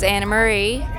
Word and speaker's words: Anna Marie: V-E-N-T-U-R-I-E-L-L-O. – My name is Anna Marie, A-N-N-A-M-A-R-I-E, Rooker, R-O-O-K-E-R.